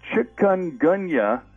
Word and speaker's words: Chikungunya: Chikungunya fever. – Chikungunya fever? – Chikungunya fever. It's spelled C-H-I-K-U-N-G-U-N-Y-A.